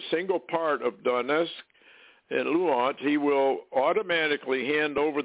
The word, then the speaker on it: Luhansk: Moscow, will not only recognize Donbass, Moscow will not agree to the recognition of the independence of Donetsk and Luhansk. Or how do they pronounce that, Larry? – Luhansk.